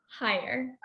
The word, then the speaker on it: Heyer: What's the correct pronunciation of your last name? – Heyer. – I knew it. – Yes. I know a lot of people think that it's Heyer because it's just H-E-Y.